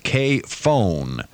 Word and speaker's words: KFON: The call is pronounced K-phone.